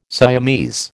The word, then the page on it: Siamese: American English